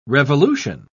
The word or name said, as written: revolution